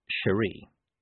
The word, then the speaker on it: Cheery: Corporal Cheery Littlebottom pronounced her name Cherie.